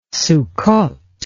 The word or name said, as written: sukkoth